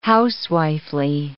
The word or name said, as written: housewifely